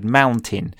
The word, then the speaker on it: mountain: I don't know why I decided to make a video, and I was showing the pronunciation of the word mountain because most students say mountain. – Mountain. Um, and I was saying how it's similar to Martin, Martin, mountain, and then I had like a little whiteboard of the word mountain.